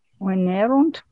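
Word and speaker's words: venērunt: Wenerunt. Wenerunt. – Yeah, wenerunt is right for the perfect. V long E N E R U N T. Wenerunt.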